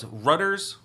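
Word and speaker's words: Rutter's: Rutters. – Rhymes with Utters.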